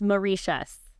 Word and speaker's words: Mauritius: Okay, I just looked it up. It's pronounced Mauritius.